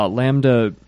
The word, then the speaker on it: Lambda: Was he talking about blocks in period, or was he also talking, or was it for Lambda, if I'm saying that right, Lambda function definitions? – Yeah, Lambda. Lambda would, functions were definitely okay.